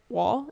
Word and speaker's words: Wahl: His name's Grant Wahl. Is that how you say it? I know that I figured the H was silent.